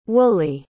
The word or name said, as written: woolley